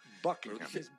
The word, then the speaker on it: Buckingham: Buckingham. – Eso sí. Y de hecho, el H medio que se es medio silente. Buckingham. Buckingham. – Más o menos. – No se pronuncia mucho.